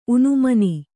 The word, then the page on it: ಊನಮಾನ: kannada